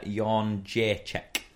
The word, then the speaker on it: Jędrzejczyk: And the other one looks like Joanna Jedrzejczyk. Definitely Polish. – Nearly. So we've got Zhang Weili and we've got Joanna Jędrzejczyk. – Yeah, I'm not getting that one.